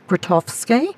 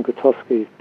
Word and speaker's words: Grotowski: and I hope I pronounce his name right, Grotowski.